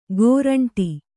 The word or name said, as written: ಕೋರಂಟಿ